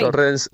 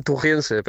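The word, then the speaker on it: Torreense: Torrense. Bueno, en Portugal, tujiense, pero tujiense, sí, torrense.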